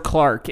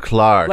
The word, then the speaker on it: clerk: A lovely bit of clerk. and I don't know. – Maybe for a lark. – Oh yeah, they, yeah. Sure they they pronounce that word Clark in England. – Lovely bit of Clark.